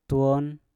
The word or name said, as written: twon